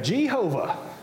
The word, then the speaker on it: Jehovah: One Latinized pronunciation of this word pops up in the Middle Ages, and you might be familiar with it as well, and it's spelled like this. And we would say, Jehovah. Probably more than likely that was meant to be pronounced something like Yahuwah, but that doesn't come from the Hebrew.